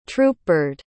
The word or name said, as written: troopbird